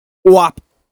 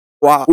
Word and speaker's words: UAP: The acronym is UAP. WAP. – WAP.